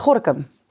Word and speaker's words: Gorkum: En waar is ehm, eh, of hoe, hoe, spreek je het uit? – Uh, eh, Gorkum.